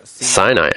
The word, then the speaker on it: Sinai: Sinai, as our sages said, means sin'ah, hatred, for they are pronounced the same, meaning the affliction entailed in every illness.